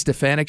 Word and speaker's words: Stefanik: How do you say her name? Stefanik? – Elise Stefanik.